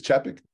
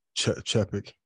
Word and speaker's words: Chapek: They, the former CEO, Bob Iger, left, and they had a new CEO, Bob, how do you pronounce his last name? – I believe it's Chapek. – Chapek. – Yep.